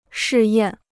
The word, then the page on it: 试验: shì yàn